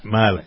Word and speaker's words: Mila: And I noticed that, is it Mila or Mila? How do you pronounce Mila? – Mila. It's Mila Kunis.